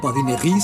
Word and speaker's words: badinerie: Badinerie se escribe badinerie y solemos pronunciar en, bueno, castellanizado, badinerie en español.